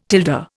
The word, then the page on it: tilde: Indian